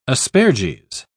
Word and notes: asperges: British English

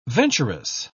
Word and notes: venturous: British English

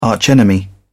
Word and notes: arch enemy: British English

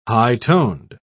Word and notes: high-toned: British English

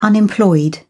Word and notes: unemployed: British English

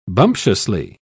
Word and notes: bumptiously: British English

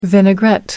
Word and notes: vinaigrette: American English

